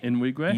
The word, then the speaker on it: Nwigwe: T-O-B-E. – Yeah. – N-W-I-G-W-E. – In Wigwam. How do you say that? In Wigwam. – In Wigwam.